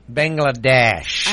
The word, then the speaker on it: Bangladesh: We have a Bang. How do you say it? Bang. – Bangladesh. – I almost said Bangkok, so I'm so glad you stepped in.